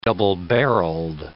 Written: double-barreled

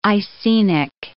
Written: Icenic

